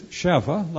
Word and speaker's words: Sheva: Now, normally we just take Sheba, or as it's pronounced in the Hebrew, is Sheva, like our word 7.